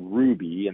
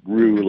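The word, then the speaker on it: Rouxbe: So we decided to partner with an organization called Ruby, and that's R-O-U-X-B-E, Rue, like, you know, flour and butter.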